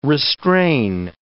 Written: restrain

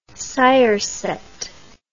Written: siroset

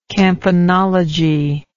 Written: campanologer

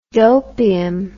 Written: dopium

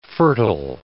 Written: fertile

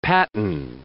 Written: paten

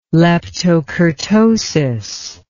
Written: leptokurtosis

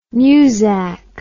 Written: newzak

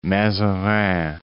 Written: Mazarin

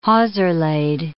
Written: hawser-laid

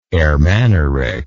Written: Ermanaric

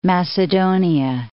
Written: Macedonia

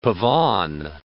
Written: pavane

